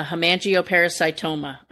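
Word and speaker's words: hemangiosarcoma: So my Doberman Pinscher, when he was about 7, got a hemangioparasitoma. I hope I'm saying that right. – Yep, you are.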